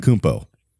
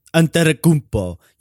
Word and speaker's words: Antetokounmpo: And how do you say his last name? – Antetokounmpo. – Antetokounmpo.